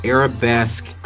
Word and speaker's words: Arabesque: But check out Arab, how you pronounce it, Arabesque Cookie. Arabesque Cookie.